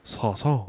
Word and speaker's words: Saison: Did I say it right? Saison. – No. Say. – Say. – Zon. – Son. – More of a Z. – Say. – Saison. Zon. – Saison. – There you go.